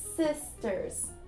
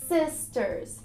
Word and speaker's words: sisters: Aqui na pronúncia eu quero que você faça o i com som de i, então ele fica também mais suave, fica sisters. Repeat with me, sisters.